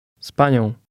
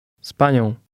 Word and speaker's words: z panią: Z panią. Nią. Nią. Panią. Z panią. Z panią. – Again, did you hear the S sound at the beginning?